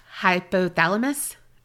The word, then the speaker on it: hypothalamus: they probably would have seen an enlarged hypothalamus.